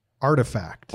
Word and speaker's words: RTFKT: I can't pronounce it. What is it? RTFKT. – Artifact. – Artifact. That's not how you spell artifact.